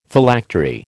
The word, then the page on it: Phylactery: American English